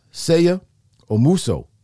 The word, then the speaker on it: Seigha Omuso: Seigha Omuso is his name. Yeah, I'm gonna win. I said it right. Did I say it right, man? – Excellent.